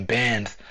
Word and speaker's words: Benz: Like my name Benz like the car, but with an S because it, well, it was too long, so it, it, it didn't rhyme with the next bar.